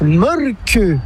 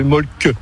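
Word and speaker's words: Mölkky: Inventé en Finlande en 1996, ce jeu de quilles s'écrit M-O-T-R-E-M-A-L-K-K-Y. – Ça s'écrit molki, mais ça se prononce molke. – Le molki. – Plutôt un E qu'un E, mais pas un U. Comme ça fait molque.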